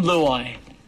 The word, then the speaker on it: Luai: Now, one of the really popular players, now I hope I pronounced his name correctly, Jerome Laluaia. – No. – Doesn't feel like it was done correctly. – Jerome Luai. – Lovely guy. Correct, yeah.